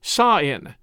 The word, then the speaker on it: Samhain: They say Celts invented Halloween, sort of, as Halloween tradition originated with the ancient Celtic festival of Samhain, which is spelled Samhain, like S-A-M-H-A-I-N, Samhain, but it's pronounced Sa-in, S-A-A-I-N. In this ancient festival of Samhain, people would light bonfires and wear costumes to ward off ghosts.